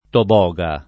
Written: toboga

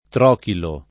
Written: trochilo

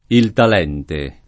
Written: Talente

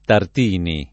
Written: Tartini

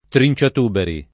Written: trinciatuberi

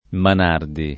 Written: Manardi

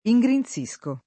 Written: ingrinzire

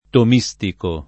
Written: tomistico

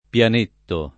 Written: pianetto

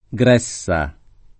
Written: Gressa

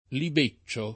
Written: libeccio

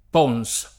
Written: Pons

